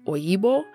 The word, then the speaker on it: Oyibo: And they do use Oyinbo in the show, but there is an Igbo pronunciation, Oyibo, but they don't say that in the show, so I'm not counting that in my list, but I did want to mention it because they are super similar, but they are pronounced differently and technically spelled differently.